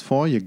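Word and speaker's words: Forge: Und da ist nämlich Tobias Forje Gastsänger. – Und Forje? zugleich aber ja. Wird der so ausgesprochen? Im Schwedischen wird das G an dieser Stelle wie ein J gesprochen. Alter.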